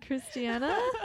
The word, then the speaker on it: Kristianna: And like Kristianna rhymes with Hannah.